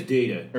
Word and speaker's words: Deida: is it Dida? – I think it is Dida. – It could be Dida. – It's spelled weird. Yeah. I'm sorry. We always butcher people's names. Yeah. I'm sorry. – We're substitute teachers. Sorry. – That's a weird name. It's David Dida. D-E-I-D-A.